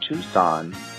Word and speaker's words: Tucson: I'm currently in the Tucson, Arizona, spelled Tucson. Just want to know, why is it pronounced Tucson? Where'd the C go?